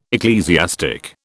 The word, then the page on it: ecclesiastic: British English